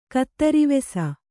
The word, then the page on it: ಕತ್ತರಿವೆಸ: Kannada